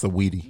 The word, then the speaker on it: Saweetie: Sweetie, nigga? – Sweet, no, it's pronounced sweaty. – It's saweetie.